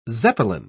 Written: zeppelin

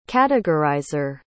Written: categorizer

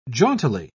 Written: jauntily

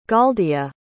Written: goldia

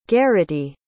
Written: garrity

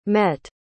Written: mette

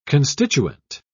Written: constituent

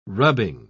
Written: rubbing

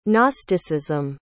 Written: gnosticism

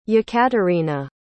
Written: yekaterina

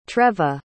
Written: treva

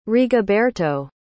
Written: rigoberto

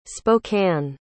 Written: Spokane

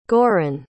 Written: goren

